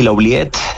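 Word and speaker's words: L'Oubliette: Sí, La Ubiette. Es un poco el término francés, como de oublier, como de olvidar, ¿no?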